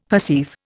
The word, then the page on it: perceive: Indian